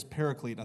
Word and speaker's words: paraclete: The Greek word there is paraclete. I think is how you say it. Am I saying that right, Tommy? I got that? Nailed it.